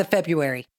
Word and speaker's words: February: I would like to point out that I was really cocky because I remembered the silent R. – Oh, I thought it was a shoo-in with the February.